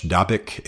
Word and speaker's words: Dabiq: D-A-B-I-Q is the way it's spelled in English. Dabik is the way it's pronounced in English.